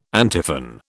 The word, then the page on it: antiphon: British English